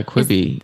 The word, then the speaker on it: Quibi: Is this like another, do you remember Quibi? Is that even how you pronounce it? – Of course. Yeah, Quibi.